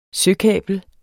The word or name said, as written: søkabel